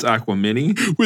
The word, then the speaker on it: Aquemini: They lived up to the hype on their third album, the easy-to-pronounce Aquemini, and took hip-hop into the future with deep storytelling. – Rob, I believe it's Aquemini.